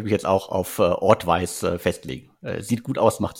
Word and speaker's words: Audvice: Auf jeden Fall der nächste Deal von Cast Capital hört auf den Namen Ortwise. Das ist irgendwie, glaube ich, die Mischung aus Audio und Advice, nehme ich an.